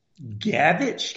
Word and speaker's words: gavage: Gavage? Can you spell that? – It's G-A-V-A-G-E, gavage.